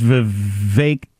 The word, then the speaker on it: Vivek: And the other guy is Vivek, rhymes with cake, Ramaswamy.